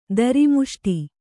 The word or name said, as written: ಧರ್ಮಿಷ್ಠ